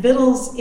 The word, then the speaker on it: victuals: The title of my book is Vittles, and Vittles is the absolute correct, and always has been, correct English pronunciation of the word that is spelled V-I-C-T-U-A-L-S, meaning we've been right all along.